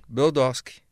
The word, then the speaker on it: Brodowski: Qual é a pronúncia correta? – Brodowski. – É a e cidade... aquele W lá, não se fala o W? É W mudo? – É, sempre foi conhecida como Brodowski.